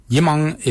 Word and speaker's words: Yimoong: Yimung is spelled Y-I-M-O-O-N-G.